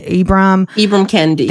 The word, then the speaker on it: Ibram X. Kendi: Ibram Kendi. – Thank you. – Ibram X. Kendi.